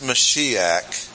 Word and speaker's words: Mashiach: Throughout the Old Testament, you have a connection between Yahweh, the only true God, and His Messiah, pronounced Mashiach in Hebrew, and the association was intimately close.